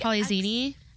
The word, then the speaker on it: Polyxeni: I love how you knew how to say that because it's spelled Polyxeni. – Because I think Polizini. – I've seen X's in names, and it was pronounced with a Z.